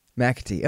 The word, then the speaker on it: McAtee: How do you say the guy's name? – McAtee. – Okay, good. Yeah. I guessed. It's just MC, not MAC, so I wasn't sure if it's, you know, McDonald or MacDonald, you know, like the burger or the comedian.